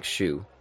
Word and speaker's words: Hsu: Yeah, even the brother, his name is spelt like H-S-U, and I had to look that up, and it's pronounced like Zhi, like, or Zhu, excuse me. – Yeah, like yeah, almost like Shu, but yeah, like a soft J for us.